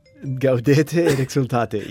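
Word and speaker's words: Gaudete et exsultate: Uh, it's called, okay, I will try to pronounce it in Latin, okay? Okay. Gaudete e exhortate. – Gaudete et exultate.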